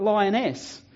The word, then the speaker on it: Lyeines: It came written L-Y-E-I-N-E-S, and I didn't know how to say it. And so when I rang up and was talking to Baptist World Aid, I said that, and they said, oh, you're the one that got Lioness.